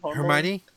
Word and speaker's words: Hermione: Um, I can never pronounce her name, Hermione. – Hermione.